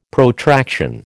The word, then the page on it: Protraction: American English